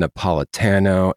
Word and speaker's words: Napolitano: Today we have the New York Times bestselling author, Anne Napolitano, and I hope I pronounced your name properly.